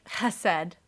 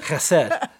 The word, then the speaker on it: chesed: You have to pronounce it with like a guttural sound, like chesed.